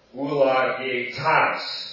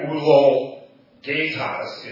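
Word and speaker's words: Ulagetas (Εὐλογητός): Ulagetas. Go ahead. Right. It's not ulogetas. It's ulagetas. That's the accent is on the final omicron there. This is an omicron. It's not an omega.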